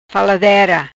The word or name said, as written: Faladera